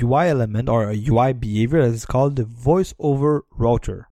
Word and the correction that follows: rotor: Router? – Yeah, no, rotor, like ROTOR. Sorry for the pronunciation.